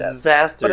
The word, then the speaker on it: Disaster: Disaster, but it's spelled like disaster. It's just disaster.